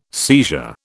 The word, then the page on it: seizure: British English